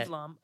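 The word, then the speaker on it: Eve Lom: Eve Lam.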